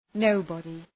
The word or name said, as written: nobody